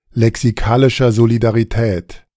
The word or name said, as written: lexikalischer Solidarität